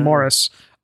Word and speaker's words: Maurice: A early version of a sequel to Amazing Maurice, or Amazing Morris, where, you know, he's using the UK pronunciation, where he was a ship's cat.